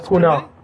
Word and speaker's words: Pune: So it's in Pune, or Pune? – Pune. Yeah. – Pune. There you go.